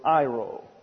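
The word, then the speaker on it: Iro (αἴρω): It is presented by the aorist passive imperative of the verb iro. A-I-R-O, pronounced Iro.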